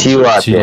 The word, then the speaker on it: тилапия: Тилапия. тилапия. Пишется, тилап. – Ну, ну, хорошо, может, может быть, тилапия на русском, я хз, я посмотрю на, на, Нет, на инглише. – По-английски, по-английски просто тилапия.